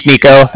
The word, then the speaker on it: Niko: So like I said, my name's Nico. That's spelled N-I-K-O. Calling from the Bank of Cardiff, and the Bank of Cardiff is spelled C-A-R-D-I-F-F, as in Frank.